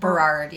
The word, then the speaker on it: Burrard: Is that how you say it? – Burrard, yeah.